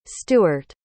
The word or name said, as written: stewart